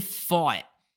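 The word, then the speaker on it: Pfaadt: So Brandon Faught, I had to Google how to pronounce his name. It's P-F-A-A-D-T. I would have thought it was something wildly different, but he's a rookie.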